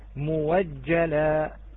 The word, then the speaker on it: مؤجلة: نعم، فإذا تبدل فتلفظ موجلة، موجلة. أبدلنا